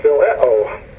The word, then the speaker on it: Phileo: Phileo, is how they say it's pronounced here.